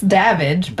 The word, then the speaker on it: Davidge: It's Davidge, but Davidge. Jerry can't say Davidge, so he says Davidge.